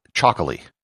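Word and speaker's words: Txakoli: It's called Chocolí, spelt with a TX instead of a CH, and it's produced in the Basque country.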